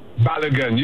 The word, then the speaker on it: Balogun: There's a young man, I believe you pronounce his name, Florin Balogun. Did I say that right, or am I messing up that name? – Balogun.